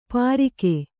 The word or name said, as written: ಪರಿಖೆ